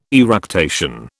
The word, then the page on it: eructation: British English